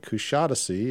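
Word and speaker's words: Kuşadası: Kushadasi is not pronounced at all like you're pronouncing it. The accent is on the first syllable and on the I, no dot, so it's more like a than e.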